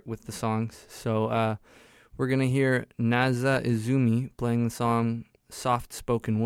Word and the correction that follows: Naya Izumi: We just heard Naya Izumi, which I mispronounced. It's Naya Izumi playing the song Soft Spoken Woman, and then Signals playing the song Lungs Apart.